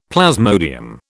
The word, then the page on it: plasmodium: British English